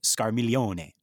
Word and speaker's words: Scarmiglione: It's Scarmiglione. Mm-hmm. So I think that is Scarmiglione. – Yeah. – Right? You don't say the G, right? – Right.